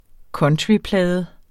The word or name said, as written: countryplade